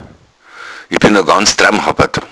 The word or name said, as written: dramhabbad